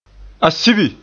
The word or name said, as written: Asibi